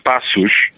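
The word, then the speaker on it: Paços: Yeah, it's a soft C. It's Passos.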